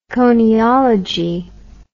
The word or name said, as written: koniology